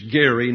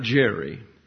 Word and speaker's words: Gerry: The way you pronounce his name is Gerry, not Jerry.